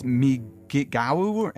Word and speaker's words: migou: It's it's a t- it's a Tibetan word, uh, and it's spelled the well, it's spelled in the Tibetan language, but it's Migawu, M I G O U and it means yeah.